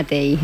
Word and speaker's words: TEI: Incorpora la conversación a, que es responsable del programa TIE, Tutoría Entre Iguales. ¿Lo he dicho bien? – Programa TEI. – TEI, perdón. Bueno, pues me he ido yo ahí la dislexia a veces.